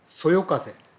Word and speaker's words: 微風: 微風と書いてそよ風と読む、このそよ風も熟字訓であり、あるドラマのセリフを借りるならば、微はそれだけではそよと読むことはできないけれど、風と出会ってそよとなるという言葉がありました。